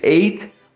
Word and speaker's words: eight: Esse T no final, que é o som do T, às vezes você pode ou não pronunciar ele, porque geralmente os americanos, eles costumam deixar esse som de lado. Então, às vezes eles pronunciam ei, ei. E esse somzinho não explode na boca. Ele quase, ele vai quase lá, mas ele não sai. Então, ao invés de eight, você pode pronunciar eight também. É super natural.